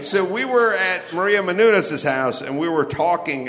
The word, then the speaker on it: Menounos: Benina Menounos with the backyard, the fire pit, nice. – Yeah, she pronounces her name Maria Menounos.